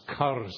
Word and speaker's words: curs: One of the fine Jewish translators of the modern world translates dogs here as curs. These are not pet dogs. These are wild dogs, curs. You need to roll your R when you say that. Curs.